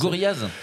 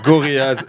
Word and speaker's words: Gorillaz: Comment Guerriers très difficile à prononcer pour nous français. – Gorillaz. – Ouais, Gorillaz, excellent.